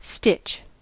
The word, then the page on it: stitch: American English